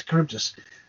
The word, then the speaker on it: Charybdis: Charybdis. – Charybdis. Yeah. – Yes, Charybdis.